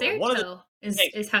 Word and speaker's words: Dexerto: Dexerto is how it's pronounced.